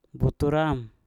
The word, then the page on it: butur am: Mpur